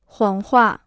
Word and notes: 谎话: huǎng huà

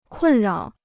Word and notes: 困扰: kùn rǎo

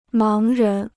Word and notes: 盲人: máng rén

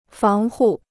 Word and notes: 防护: fáng hù

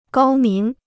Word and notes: 高明: gāo míng

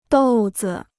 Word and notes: 豆子: dòu zi

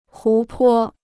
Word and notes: 湖泊: hú pō

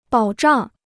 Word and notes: 保障: bǎo zhàng

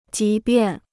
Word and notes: 即便: jí biàn

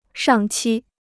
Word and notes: 上期: shàng qī

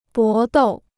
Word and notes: 搏斗: bó dòu

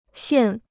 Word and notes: 腺: xiàn

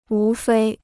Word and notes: 无非: wú fēi

